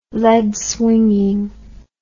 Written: lead-swinging

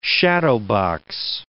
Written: shadowbox

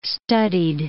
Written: studied

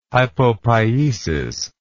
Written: hypopiesis

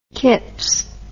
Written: Kipps

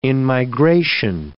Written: in-migration

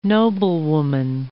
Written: noblewoman

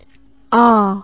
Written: ahh

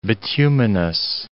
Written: bituminous

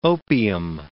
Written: opium

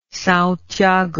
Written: Sao Tiago

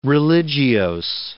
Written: religiose

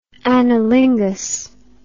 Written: analingus

